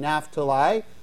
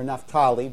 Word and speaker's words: Naphtali: So Jesus moves into the territory of the old Jewish tribal allotments of Zebulun and Naphtali, or Naphtali. Both are acceptable pronunciations, by the way, in fulfillment of the prophecy that's found in Isaiah.